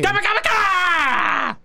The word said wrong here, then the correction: Kamehameha: Bro, you're saying it wrong. It's like an A. It's just Kamehameha.